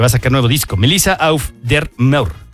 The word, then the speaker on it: Melissa Auf der Maur: Melissa Auf der Maur. – Sonó bien. ¿Cómo lo dijiste? – Auf der Maur. Fue como Final Decision.